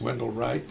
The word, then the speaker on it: Wendell Wright: The real name is Wendell Wright, W-E-N-D-E-L-L-W-R-I-G-H-T.